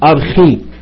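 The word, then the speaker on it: ارخي (ἀρχή): الراجل اختار ان هو بينطق خي مش شي، ان ارخي.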